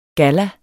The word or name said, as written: Galla